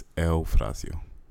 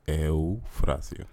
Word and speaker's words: Eufrasio: How do you really pronounce it? – It's Eufrasio. – Say that again. – Eufrasio.